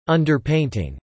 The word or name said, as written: underpainting